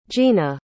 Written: gina

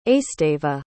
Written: esteva

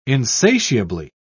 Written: insatiably

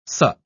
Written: sup

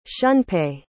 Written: shunpei

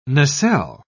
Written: nacelle

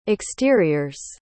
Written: exteriors

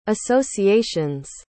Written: association's

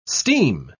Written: steam

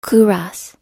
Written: kouros

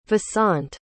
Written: vasant

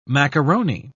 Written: macaroni

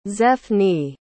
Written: zephne

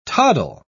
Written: toddle

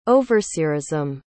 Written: overseerism

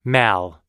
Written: mal

